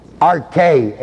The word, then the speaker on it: arche: And the word power there is the word Arche, A-R-C-H long E.